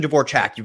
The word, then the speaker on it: Dvorchak: I should start being like, no, it's actually Dvorchak. You really slammed the A.